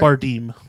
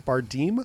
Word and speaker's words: Bardem: So we get Jennifer Lawrence, who's mother, and Javier Bardem. Am I saying that right? – Bardem. – Bardem. – Yeah, it's an A.